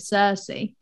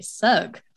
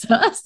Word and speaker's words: Circe: Lauren, don't be shocked that I've just pronounced it Circe, because yes, I have been pronouncing it Cirque for the last year. And then I thought, hang on, we're recording a podcast, I should just double check, because a lot of the time I'm an illiterate fuck. And I was. It's pronounced Circe.